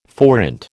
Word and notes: forint: American English

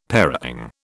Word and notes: parang: British English

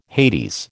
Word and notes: Hades: American English

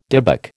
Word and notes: dybbuk: American English

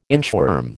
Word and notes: inchworm: American English

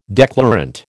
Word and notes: declarant: American English

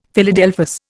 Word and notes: Philadelphus: Indian

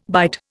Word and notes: bite: Indian

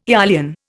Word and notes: eolian: Indian

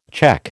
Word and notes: cheque: American English

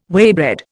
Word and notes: waybread: Indian

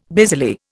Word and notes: busily: Indian

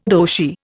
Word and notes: Roshi: Indian

